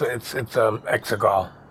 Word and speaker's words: Exegol: Yeah, it's Exegol. That's how it's pronounced.